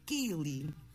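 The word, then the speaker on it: ceilidh: Pronuncia-se mais ou menos como Kili. Kili é mais ou menos isto.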